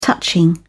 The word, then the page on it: touching: British English